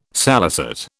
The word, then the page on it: salicet: British English